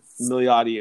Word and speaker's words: Milioti: So it stars Andy Samberg and Kristen Milioti, if I pronounced that wrong, I'm sorry.